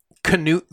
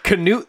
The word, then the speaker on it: Canute: Canute the Great. Oh, you laugh now. His name is spelled C-N-U-T. – Charisma, nerve, uniqueness, and talent. – Canute the Great had a law code called the Ecclesiastical Ordinances.